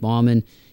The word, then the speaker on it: Bauman: Type in my name, Mike Bauman. You can also follow me on Twitter at mbauman5, B-A-U-M-A-N, that's how you spell it.